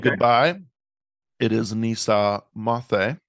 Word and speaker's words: moce: Mathe. – Nisa Mathe. – Which is spelled M-O-C-E, but the C, I guess, is pronounced more like the kind of a Barcelona sort of thing.